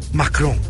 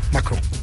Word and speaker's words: Macron: Como decíamos, habla en un rato Donald Trump y más tarde Keiko Fujimori, José Antonio Kast y Emmanuel Macron. Cecilia, ¿lo dije bien? – Sí, muy bien. – Macron.